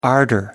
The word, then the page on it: ardor: British English